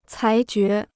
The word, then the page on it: 裁决: cái jué